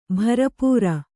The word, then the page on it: ಭರಪೂರ: kannada